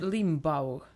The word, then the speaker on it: Limbaugh: Rush Limbo? Heter han så? Hur ser uttalar hans namn då? – Alltså, nej, nej, nej, men det är bara roligt att han heter Rush Limbaugh. – Rush Limbaugh.